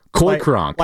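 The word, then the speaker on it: Cronk: That is spelled C-O-Y-C-R-O-N-K. – Kronk? – Coy Kronk. – Like Kronk from Emperor's New Groove? – That's exactly. – Oh, yeah. It's all coming together. – That's exactly right.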